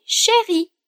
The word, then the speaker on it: chéri: E, por último, a gente tem chéri na frase, que é uma maneira super carinhosa de tratar alguém. E a pronúncia é a mesma para o feminino e para o masculino.